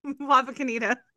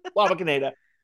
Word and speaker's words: Wapakoneta: Wapakoneta. – Wapakoneta.